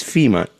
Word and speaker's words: Fima: My name is Fima, just like the organization, but spelled with an I.